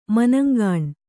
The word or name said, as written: ಮನಂಗಾಣ್